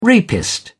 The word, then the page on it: rapist: British English